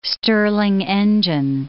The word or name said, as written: Stirling engine